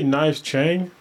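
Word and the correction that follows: Knives Chau: Knives Chow. – Chow. That's what. – Yeah, I was saying it wrong.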